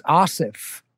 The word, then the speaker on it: Asif: It's Asif. – Asif. – Yeah. – Asif. So it is the softer like Anna.